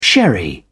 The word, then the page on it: sherry: British English